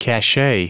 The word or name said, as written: cachet